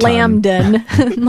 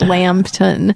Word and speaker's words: Lambton: That's spelled Lambton. It's not Lambden, like I thought for most of the movie. It's Lambton, Connecticut, a small town, a small, very Christmassy town.